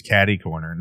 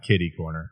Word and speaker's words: catty corner: And please, catty corner, not kitty corner.